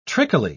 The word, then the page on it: trickily: British English